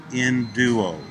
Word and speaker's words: enduo: That word strong is the word enduo. It's spelled E-N-D-U-O, enduo.